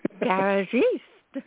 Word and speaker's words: Garagiste: Garagiste.